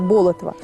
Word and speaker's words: Болотов: Здесь отмечают 280 лет со дня рождения Андрея Тимофеевича Болотова. Но сейчас, как выяснили, правильно говорить Болотов.